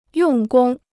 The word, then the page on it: 用功: yòng gōng